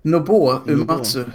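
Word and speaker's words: Nobuo Uematsu: Eh, den store Nob eh, Nobuo, eller hur uttalar du honom? – Nobuo Umatsu. Matsu.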